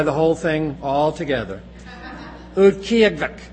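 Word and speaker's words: Utqiaġvik: If I were to try to write this out for you phonetically in English, I would not spell it capital K, capital Y, capital U, capital G as kyug, and not quite capital K-E-Y, capital U-G, ki-ug. It's almost like capital K, small y, U-G. It's kyug.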